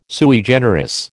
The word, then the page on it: sui generis: American English